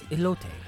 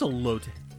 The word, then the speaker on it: elote: They one side is for the white people that can't pronounce anything in Spanish, oh, and then this is elote. – What is a lot? – It is elote.